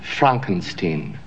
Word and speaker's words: Frankenstein: My name. It's pronounced Frankenstein.